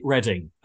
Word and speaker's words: Reading: It's actually Reading, pronounced Reading, spelt Reading.